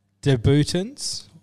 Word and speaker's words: debutants: Debutants is how it's pronounced.